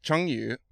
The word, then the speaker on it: Chengyu: Now, the first thing is, is we should probably get you to pronounce the word Chengyu, which I butchered. – Yes. It's Chengyu, which is